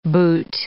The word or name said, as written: boot